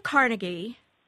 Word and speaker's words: Carnegie: Andrew Carnegie was Scottish, and in Scotland, his name would be pronounced Carnegie. And it was pronounced Carnegie a lot when he was alive. Over time, it got more or less Americanized into Carnegie. But you'll still find a lot of, like Carnegie Mellon. It's always pronounced Carnegie. But you still will find people who say Carnegie. – Me. – You say Carnegie? – Yes. – Right. That's the original Scottish pronunciation.